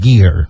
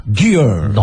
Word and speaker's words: Gere: Esse é Richard Gere. Gere. Olha. É Richard Gere.